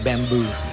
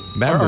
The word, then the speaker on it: bamboo: Big fuss about like how to pronounce bamboo. – Why? Was bamboo. – Kasi diba parang a lot of us parang we say bamboo. – Bamboo. – Or bamboo. – Yeah. Yeah. – Hindi dapat bamboo. Hindi dapat bamboo. Dapat bamboo. – Yeah. It's on the second syllable.